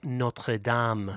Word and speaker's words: Notre Dame: It's pronounced Notre Dame. Notre Dame. Come on, it's not a university in, in uh, Notre uh, Indiana.